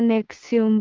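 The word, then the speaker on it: NXIVM: Chibn, pronunciado diagonal inversa Nexium diagonal inversa,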